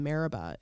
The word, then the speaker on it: Meribah: I have a very unique name, Meribah.